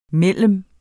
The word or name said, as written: mellem